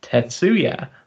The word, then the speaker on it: Tetsuya: It's like how you don't say High 69, it's Hiroki, and T28 is actually pronounced Tetsuya.